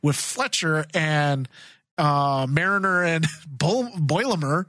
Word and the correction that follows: Boimler: Yeah. – Boimler. – Yeah. You're the one who was saying it right last week.